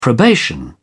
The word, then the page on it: probation: British English